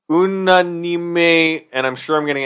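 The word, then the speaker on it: Unánime: Michael just corrected my pronunciation. I'm usually a bit of a bitch at correcting his pronunciation when he does French. Say that again, please. – Unanime. – I think that's correct.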